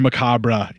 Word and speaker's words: macabre: I technically used that DVD as like packaging to protect Macabre, which we were talking about tonight. – Which is much more important. – Or Macabre, if you want to pronounce it like they do in the trailer.